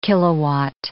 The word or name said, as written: kilowatt